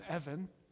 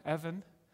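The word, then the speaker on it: Evan (אבן): The word Eben, it's actually pronounced Evan in Hebrew, means stone. Evan means stone in Hebrew.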